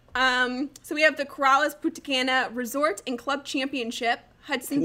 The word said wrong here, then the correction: Punta Cana: How would you say it, Josh? – Punta Cana. – Punta Cana. – There's an N in there, yeah.